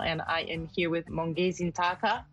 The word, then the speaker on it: Mongezi Ntaka: I'm saying that right. – Yes, that's correct. – Not perfectly, though. I can tell. – Yeah. So I'm not saying it. Now, the way how it's pronounced, it's Mongezi. I said Ntaka. – Mongezi Ntaka. – That's correct, yes.